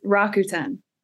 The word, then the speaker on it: Rakuten: Did I say that correctly? Rakuten? – Close. Rakuten. Yeah. – Rakuten. I apologize.